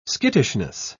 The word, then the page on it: skittishness: British English